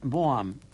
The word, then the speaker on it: Vaughan: Es pronuncia Boam, però s'escriu en anglès Vaughan.